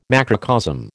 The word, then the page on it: macrocosm: American English